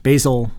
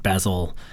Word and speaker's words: Basil: His name is pronounced Basil, like the herb, and not Basil, like the British pronunciation.